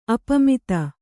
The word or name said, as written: ಅಪಮಿತ